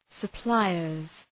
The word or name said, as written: suppliers